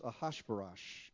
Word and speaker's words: Ahasuerus: Esther, now she's a Jew, but she presents herself with other women before the king, it's pronounced Ahasuerus, Ash, Gosh, Begosh.